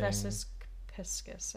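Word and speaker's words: Vesica Piscis: Vesis Piscis, I think. – Wow, I always say it wrong. I always say Viridis Q because there's a Daft Punk song that gets stuck in my head that has the same name.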